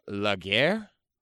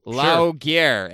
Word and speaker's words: Laugier: This is a flick by, I'm going to butcher the name, you can correct me because it's French, Pascal Laguerre? Laguerre? – I'm not looking at it, so Laguerre, L-A-U-G-I-E-R. Pascal Laguerre.